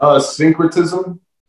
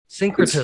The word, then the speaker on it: syncretism: Syncretism. – Syncretism. – It's spelled S-Y-N-C-R-E-T-I-C. Syncretic. I-S-M.